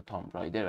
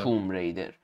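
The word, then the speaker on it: Tomb Raider: ما ایرانی عادت داریم میگیم حتی تام ریدر، ولی خب تلفظ درستش میشه توم ریدر، و دوس اکس و یه دونه دیگه هم بود از مهماش که حالا شاید هم خیلی مهم نبود.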